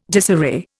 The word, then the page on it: disarray: Indian